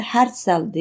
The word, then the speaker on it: Herzl: Nitekim Herzl o tarihe göre ya da Herzl diye okunuyor özür dilerim.